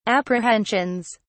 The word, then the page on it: apprehensions: British English